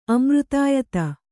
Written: ಅಮೃತಾಯತ

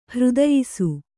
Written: ಹೃದಯಿಸು

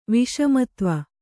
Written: ವಿಷಮತ್ವ

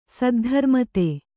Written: ಸದ್ಧರ್ಮತೆ

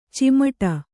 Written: ಚಿಮುಟು

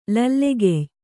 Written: ಲಲ್ಲೆಗೆಯ್

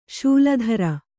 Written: ಶೂಲಧರ

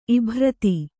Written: ಇಭ್ರತಿ

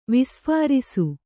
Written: ವಿಸ್ಫುರಿಸು

